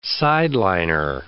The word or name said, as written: sideliner